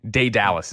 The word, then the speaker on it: Daedalus: You just nailed it, though. Daedalus is how you say it.